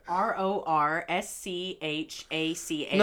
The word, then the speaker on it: Rorschach: That's the way it's spelled. – Okay, that's it. – Raw shake. Read that. – Okay. – R O R S C H A C H. – No, say the word. – Rorschach.